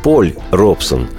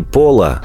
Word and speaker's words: Пол Робсон: И, честно говоря, мне не хочется называть его правильно. Просто тогда потеряется аромат мифологии и исторического очарования. Так что пусть простят меня педанты и ревнители правильного произношения. Повествование мое пойдет о человеке по имени Поль Робсон – Именно его, не Пола, а Поля Робсона, обожали в нашей стране до невероятности.